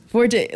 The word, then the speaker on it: forte: Forte, like, you're in music. You know forte means to get louder. – Yes. – The French pronunciation is just fort.